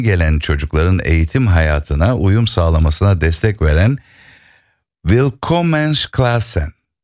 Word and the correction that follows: Willkommensklassen: telaffuzunu söyleyeyim bana. – Willkommensklassen. – Ha, Willkommensklassen. Benimki olmamış, evet. Babanız da akademisyen olarak Fransa'da doktorasını yaptıktan sonra bankacılık sektöründe çalışıyor.